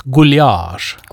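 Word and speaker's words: gulyás: Või siis noh, kui hääldada ungari pärast, siis guljaš.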